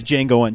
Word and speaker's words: Django: It's Django Unchained. The D is silent, bitch.